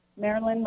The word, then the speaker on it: Marilyn: Yeah, my name is spelled Marilyn. Marilyn, like Marilyn Monroe, but we don't look the same.